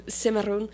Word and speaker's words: Cimarron: So Maroon comes from the term Cimarron, which is a Spanish word. I pronounced that wrong. I pronounced it like it was French. Anyway, Cimarron, which is a term for livestock that has gone feral.